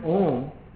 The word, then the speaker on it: Om: The word Om consists of three syllables, A, U, and Ma.